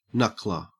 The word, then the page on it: knuckler: Australian English